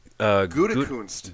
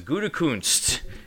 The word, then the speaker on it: Gutekunst: Gutekunst. – Gutekunst.